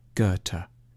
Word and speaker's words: Goethe: A safe bet is to start with a hard G on G and end with a T, Goethe.